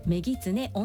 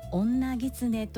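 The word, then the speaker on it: 女狐: 正しくはメギツネオンザランなのに対し、女ギツネというふうに発音してしまいました。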